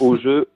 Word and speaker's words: OGEU: Bonjour Nathalie, oui, c'est bien ça, c'est OGE, O-G-E-U.